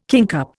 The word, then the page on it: kingcup: Indian